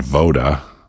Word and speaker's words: Voda: Up next, I've got something from a Polish band called Voda, spelled like Yoda but with a V.